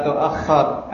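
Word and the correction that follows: tu'akhir (تؤخر): La tu'akhir atau la tu'akhar? Hah? La tu'akhir amal al-yawmi ilal ghadi.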